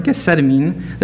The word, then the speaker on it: Gethsemane: The Garden of Gethsemane, as Drew told me, that's how it's pronounced.